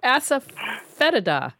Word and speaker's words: Asafoetida: Asafoetida.